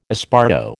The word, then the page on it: esparto: American English